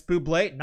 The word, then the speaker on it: Bublé: Yeah, Bublé. Right. Or bubble, however you want to pronounce it. – Cam and bubbly. – Yeah. – One of the greatest SNL skits of all time. – Oh, yeah. – John Hamm and Michael Bubbly. It's Bublé. Not anymore.